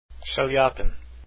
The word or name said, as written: chaliapin